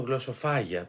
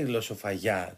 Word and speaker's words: γλωσσοφαγιά: Υπάρχει μεγάλη, και συγκεκριμένα δεν ξέρω γιατί το τονίζουν γλωσσοφάγια, το λένε και λάθος και τονισμένο. Υπάρχει μεγάλη γλωσσοφαγιά, τέλος πάντων, μέσα στο σπίτι.